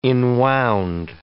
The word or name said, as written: enwound